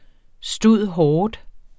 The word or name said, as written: stud.hort.